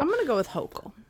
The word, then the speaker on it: Hochul: Kathy Hochul. – Hochul, thank you.